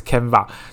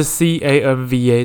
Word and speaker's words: Canva: 它怎么念？Canva 还是 Canva？就是 C A N V A 这个网站，它是一个设计各式各样，比如说脸书 Po 文啊，或者 IG Po 文，甚至可以设计履历的一个网站。